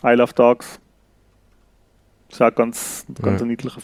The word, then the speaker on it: Isle of Dogs: Ah, also das schreibt man ja Insel, I-S-L-E, genau. Isle of Dogs, genau.